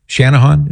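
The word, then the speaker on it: Channahon: We'll go now to Haiti in, let me see how to pronounce this, Chanahan, Illinois. Shanahan, is that right? – That is correct.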